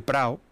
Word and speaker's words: Prado: El Prado, que aquí lo pronuncian como el Prao, es bastante extenso.